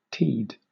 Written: teed

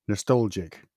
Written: nostalgic

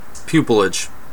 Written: pupilage

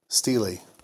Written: stele

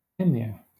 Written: emir

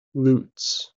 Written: loots

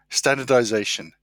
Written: standardization

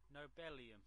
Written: nobelium

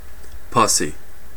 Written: pussy

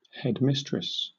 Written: headmistress

